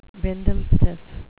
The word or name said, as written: bindlestiff